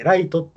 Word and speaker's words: 月: やけん、月って書いて、ライトってこう、無理やりの読み方にして、八神ライトって生まれたんや。